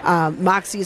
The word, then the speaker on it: MXE: So that's Moxie. Moxie is how you pronounce it, but it's spelled M-X-E.